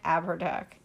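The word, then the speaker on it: Abhartach: The legend of the Irish king, Abertach, I think that's how you say it, Abertach.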